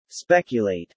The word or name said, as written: speculate